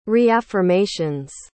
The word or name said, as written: reaffirmations